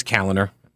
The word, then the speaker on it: Kaliner: I'm Pete, the last name is Callinor, and it's spelled K-A-L-I-N-E-R.